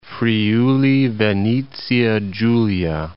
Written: Friuli-Venezia Giulia

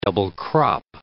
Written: double-crop

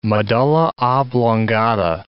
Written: medulla oblongata

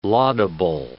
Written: laudable

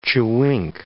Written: chewink

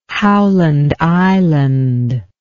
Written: Howland Island

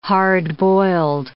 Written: hard-boiled

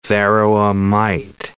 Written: varroa mite

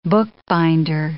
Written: bookbinder

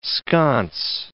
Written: sconce

